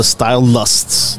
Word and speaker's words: Style Lust: They're called the Style Lusts. The Style Lusts. – Style Lusts. – Style Lusts.